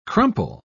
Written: crumple

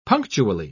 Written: punctually

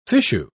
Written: fichu

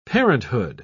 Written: parenthood

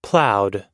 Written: ploughed

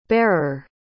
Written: barer

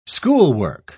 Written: schoolwork

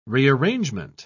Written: rearrangement

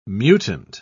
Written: mutant

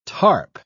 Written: tarp

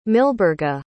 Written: milburga

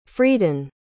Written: friedan

